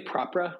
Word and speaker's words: Propra: We say Propra, like property, and then Propra.